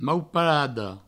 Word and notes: mauparada: mau-parado

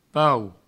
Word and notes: pau: provencal

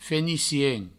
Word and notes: fenicien: Provençal

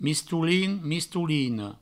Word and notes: mistolin: mistolina; mistoulin; mistoulino

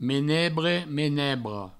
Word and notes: menebre: menebra; menebro

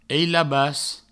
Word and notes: ailabas: eilabas

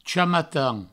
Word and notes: chamatan: provençal

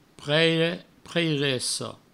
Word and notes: preire, preiressa: preire, preiresso; provençal

